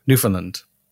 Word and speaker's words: Newfoundland: Which is Newfoundland, by the way. You'd pronounce the land. – Newfoundland.